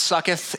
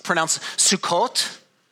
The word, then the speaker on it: Sukkot: Okay, it's actually not pronounced sucketh. It's pronounced Sukkot, but it sucketh is way better to say.